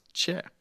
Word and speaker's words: Choi: If you want to Google him, his last name is spelled C-H-O-I. You might think Choi, but it's pronounced Che.